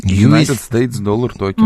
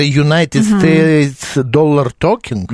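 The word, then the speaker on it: USDT: 287 United States доллар токен. – Молодец. Да. Мужчина сразу скрылся, теперь его разыскивает полиция. – Это United States доллар токен? – Да. – Это вот так читается.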